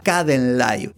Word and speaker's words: Kdenlive: Se llama Kdenlive y se escribe de la siguiente manera: K de karate, D de dedo, o sea, Kden. Imagínate la palabra vida en inglés, live, bueno, sí, Kdenlive es un software gratuito y de código abierto.